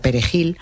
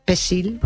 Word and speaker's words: perejil: Los soldados de Trujillo detectaban a los que había que matar haciéndoles pronunciar la palabra Perejil, porque aunque supieran castellano, esa palabra no sabían pronunciarla. Y si decían, en vez de Perejil, decían Pesil, que es como lo llamaban, sí, sí. Pesil, lo agarraban y lo mataban.